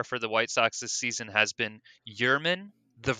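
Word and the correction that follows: Yermín: Vermin, Mercedes, it's actually pronounced "you're mean.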